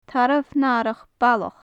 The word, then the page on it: tarbh nathrach ballach: gaelic